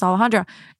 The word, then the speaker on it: Alejandra: I mean, it's constantly an uphill battle because so many people can't pronounce Alejandra. And people have even come on here and been like, you're not even pronouncing your name correctly because I'm actually saying it in the American way.